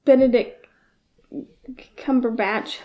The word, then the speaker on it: Benedict Cumberbatch: But, like, Benedict Cumberbatch, I think is his name. – I think you said it right. – Yeah, I think I actually said it right.